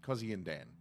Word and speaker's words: Kozyndan: But it's spelled Kozyndan. Cozy and Dan.